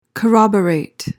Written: corroborate

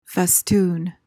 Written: festoon

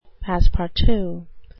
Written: passe-partout

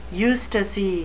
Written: eustasy

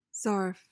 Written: zarf

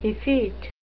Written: effete

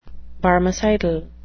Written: barmecidal

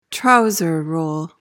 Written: trouser role

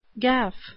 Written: gaff

